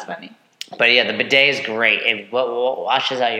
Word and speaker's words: bidet: Just so a, bid- if, a bidet. – Yeah, that's how you say it.